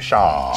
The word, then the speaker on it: Schaal: Am I pronouncing that right? Schaal. – Schaal. – Schaal. There you go. – The longer you can make it, the better.